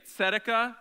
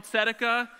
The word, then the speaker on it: tzedakah: Just like turn to somebody and say, Tzedekah. You got to say it like you're, you know, almost going to spit on them. Tzedekah.